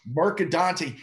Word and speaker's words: Mercadante: It's exactly like it looks like, Mercadante. – Mercadante, he is invading Nashville.